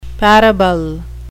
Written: parable

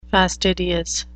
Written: fastidious

